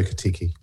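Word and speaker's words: Ekitike: Ekitiki, is it? – Yeah, Hugo Ekitiki.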